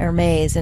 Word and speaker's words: Hermes: I also said Hermes, and it's a silent H.